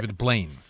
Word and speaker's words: Blaine: was it David Bain? Am I saying it right? – David Blaine. – Blaine. And, you know, you look, you say, that's remarkable, but it's probably tricks.